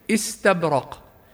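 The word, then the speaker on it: إستبرق: ومثلها مثلا كلمة استبرق في قوله سبحانه متكئين على فرش بطائنها من استبرق، فهي كما سمعنا وفي حالة الوصل هذه بقيت همزة قطع ولا تلفظ همزة وصل أبدا، من استبرق.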